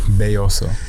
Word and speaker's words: Belloso: Belloso.